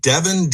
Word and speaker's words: Devon: Devon? – You are.